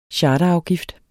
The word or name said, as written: charterafgift